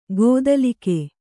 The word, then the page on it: ಗೋದಲಿಕೆ: gōdalike